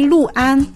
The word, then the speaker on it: 六安: 六字呢，在地名，比如安徽六安、江苏陆河这里使用的时候，当地人会读作路，这样的提示也增加到了词典当中。